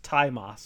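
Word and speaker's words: Tzi Ma: Oh, it's pronounced Taima, sorry. – Taima. – It's spelled T-Z-I, but it's pronounced Taima.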